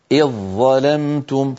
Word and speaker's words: إذ ظلمتم: لكننا عند وصل كلمة إذ بكلمة ظلمتم نحول الذال إلى ظاء ويصير النطق بظاء مشددة، هكذا إذ ظاء إذ ظلمتم، فإن وقفنا نقول إذ، تعود الذال للظهور لأنها انفصلت صوتيا عن الظاء بعدها.